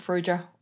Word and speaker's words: Farrugia: So my last name is spelled F-A-R-R-U-G-I-A. So it's Brooke Ferugia, all one word.